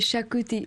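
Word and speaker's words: Xacuti: C'est Shakuti. Du coup, l'ex en Konkani, on dit che, un peu comme en Pays Basque, je crois. L'ex se transforme en che. Et c'est Shakuti Masala. – Le Shakuti Masala, alors qu'est-ce que c'est? – C'est beaucoup de épices, c'est vraiment beaucoup.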